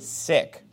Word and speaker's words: Sikh: Now, Sikhism teaches that if you're going to be a Sikh, now some people will pronounce this a Sikh, but it's actually pronounced sick, like when you're not well, you're sick. That's actually how it's pronounced.